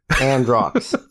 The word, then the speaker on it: Androux: A-N-D-R-O-U-X. Androx. The U is silent.